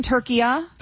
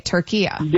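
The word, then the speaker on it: Türkiye: And so now the instruction is that also in English, say it like how it's essentially supposed to be pronounced. Okay, Turkiya. – There you go. That's perfect. That's how it's pronounced. It's always been pronounced that in Arabic language.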